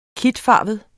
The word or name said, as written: kitfarvet